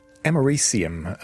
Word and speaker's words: americium: Americium, I think, is the correct pronunciation of that.